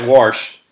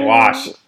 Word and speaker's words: wash: It's like Warsh. Come on, dude. That's not how you say it. Wash.